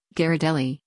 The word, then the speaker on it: Ghirardelli: Now known around the world for its fine chocolates, Ghirardelli, pronounced Ghirardelli, started in San Francisco in 1852 as a confectionery shop called Ghirardelli and Girard.